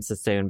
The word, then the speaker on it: Sassoon: First of all, love the last name Sassoon because of Vidal Sassoon. – Oh, I was going to think that it rhymes with bassoon.